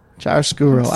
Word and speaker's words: chiaroscuro: Yeah, you mentioned in our docket, you know, how do you pronounce it? Is it chiaroscuro or chiaroscuro? – Chiaroscuro. I was somewhat close.